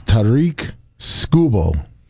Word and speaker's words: Tarik Skubal: How to say his name is, it's Tarik Skubal.